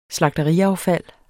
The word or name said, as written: slagteriaffald